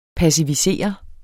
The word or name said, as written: PASSIVISERER